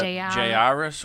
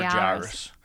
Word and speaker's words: Jairus: So I would accept Jairus or Jairus.